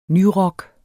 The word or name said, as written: nyrock